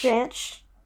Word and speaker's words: Jansch: It's J-A-N-S-C-H. – Jansch.